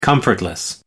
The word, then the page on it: comfortless: American English